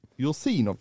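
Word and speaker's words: Jursinov (Юрзинов): Då får Vladimir, nu ska vi se så jag uttalar det rätt, Jursinov, kan det vara rätt? – Det är helt rätt.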